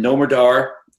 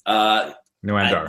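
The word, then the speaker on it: Noam Dar: Tony Nese, Brian Kendrick, and Nomadar. – Noamdar.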